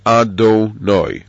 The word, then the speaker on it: Adonai: It's pronounced as if it would be written with an Aleph, Dalet, Nun, Yud, and vocalized as Adonai.